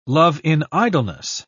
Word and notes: love-in-idleness: British English

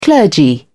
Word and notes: clergy: British English

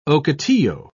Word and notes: ocotillo: British English

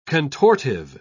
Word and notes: contortive: British English

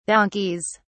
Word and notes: donkeys: British English

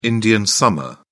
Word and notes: indian summer: British English

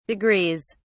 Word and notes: degrees: British English

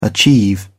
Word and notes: achieve: British English

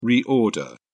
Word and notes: reorder: British English

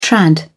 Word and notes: trad: British English

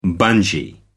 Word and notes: bungee: American English

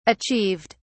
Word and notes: achieved: British English

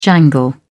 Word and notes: jangle: British English

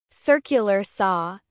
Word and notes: circular saw: American English

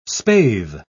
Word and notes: spathe: British English

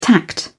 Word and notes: tact: British English

